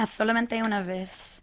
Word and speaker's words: Solamente Una Vez: like I said, forgive me if I can't pronounce things perfectly, but Solamente Una Vez? – Yeah, Solamente Una Vez.